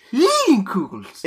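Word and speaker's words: Leinenkugel's: We have Leinenkugels, which, Leinenkugels. – If you don't know how to say it, the pronunciation is on the can.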